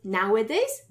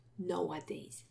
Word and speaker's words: nowadays: Be careful of pronunciation. Lots of Poles confuse that and switch the two initial vowels, samogłoski. So it's nowadays, not nowadays. No, not Noah, now.